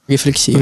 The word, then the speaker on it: рефлексия: Я же правильно, да, ударение поставил? – Рефлексия.